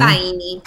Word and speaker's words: Faini: Decíamos una canción compuesta y producida por el productor italiano Dark Dust, ¿cómo se dice? Nombre artístico de Dario... Pifani. – Faini. – Ah, Faini, perdón. Dario Faini, ok.